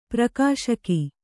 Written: ಪ್ರಕಾಶಕ

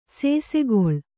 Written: ಸಸಿಗಲ್

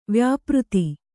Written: ವ್ಯಾಪೃತ

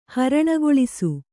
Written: ಹರಣಗೊಳಿಸು

